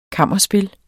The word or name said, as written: kammerspil